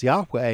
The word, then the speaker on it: Yahweh: The exact pronunciation of this is widely debated, as are all aspects of this word, but I pronounce it Yahweh. Without going into the details, Yahweh is the one and only true God,